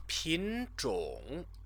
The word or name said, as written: pinzhong